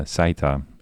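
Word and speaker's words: Seita: dat is de jonge Saita en haar zusje Zetsko, zeg ik dat goed zo? Ja.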